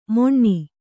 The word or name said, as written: ಮೊಣ್ಣಿ